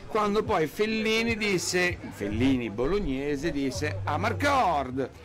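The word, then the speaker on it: Amarcord: Però in realtà la pronuncia esatta sarebbe a Marcord, mi ricordo, no, di quella cosa.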